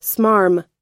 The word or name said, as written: smarm